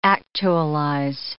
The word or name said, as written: actualize